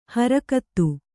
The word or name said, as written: ಹರಕತ್ತು